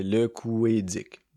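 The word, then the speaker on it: Couëdic: Prends une chance c'est vrai que souvent que le tréma, il fuck le monde, puis tu sais, ça fait O-U-E tréma. Euh, mais dans le fond, faut le prononcer comme si c'était un E accent aigu. C'est ça le, petit truc. Fait que ça fait le couédic, simplement.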